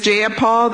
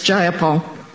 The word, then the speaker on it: Jayapal: Do you agree with Ms. Jayapal that there was no takeover? It was just Jayapal. If you're going to say my name, please say it right. It's Jayapal.